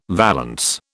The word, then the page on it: valance: British English